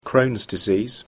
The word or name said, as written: Crohn's disease